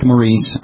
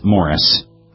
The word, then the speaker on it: Maurice: Now, it looks like Maurice. I believe it may be pronounced Morris.